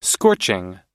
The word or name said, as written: scorching